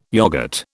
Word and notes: yoghurt: British English

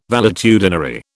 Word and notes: valetudinary: British English

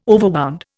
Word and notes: overwound: Indian

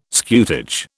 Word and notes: scutage: British English

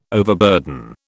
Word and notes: overburden: British English

